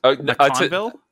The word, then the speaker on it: MacConville: How do you say your last name? I'm just curious. – MacConville? Yeah, you nailed it.